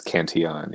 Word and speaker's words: Cantillon: If it's French, it's Cantillon, yeah. – Well, but yeah, he was Irish, but then lived in French, so both of those two pronunciations are acceptable, but Cotillion is not.